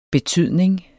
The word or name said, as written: betydning